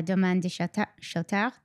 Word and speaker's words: Domaine de Chautard: Yeah, I do want to talk about Rose Centifolia, and I think it's a, a good segue into uh, Domaine de Chautard. Did I say it correctly? – Yes.